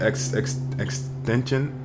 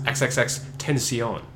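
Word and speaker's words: XXXTentacion: No, it's actually pronounced XXX Tension. – Tension, really? – Yeah, like he did an interview with like a radio station, and they asked him like, "How do you say your name?" He's like, "Oh, it's XXX Tension." So you have to say it with an accent. – With a French accent? – Tension.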